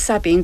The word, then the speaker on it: Sabine: It's Sabine, very good.